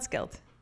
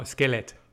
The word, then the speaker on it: skelet: So it's not skelt. – No, skelet. – Skelet. – You pronounce every letter.